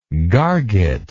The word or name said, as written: gargety